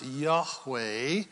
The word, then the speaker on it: Yahweh: YHWH, if you will, and that is pronounced Yahweh.